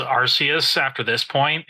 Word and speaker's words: Arceus: Arceus, Acreus, whatever. – Arceus. – Arceus, yeah. I think that's what we decided on. – That is the official pronunciation.